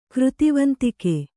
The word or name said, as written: ಕೃತಿವಂತಿಕೆ